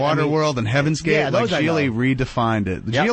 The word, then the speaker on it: Gigli: Oh, Geely? – It's called Geely. – How do you spell that? – G-I-G-L-I.